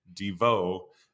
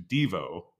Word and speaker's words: Devo: And actually they were, I was listening to a couple interviews where they have, I think for a period, they were pronouncing the name of the band Devo as opposed to Devo, you know, putting the accent on the second syllable.